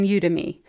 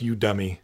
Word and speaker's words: Udemy: I think it's like Academy, but for you. – Udemy? – But it sounds like You Dummy.